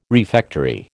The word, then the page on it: refectory: American English